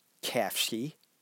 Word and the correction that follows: Kashfi: It's spelled, okay, here we go. It's spelled, maybe you can help me out. K-A-S-H-F-I. Kashfi. Okay, and maybe if you say it slow enough, it works better. Kashfi. I'll have to practice that.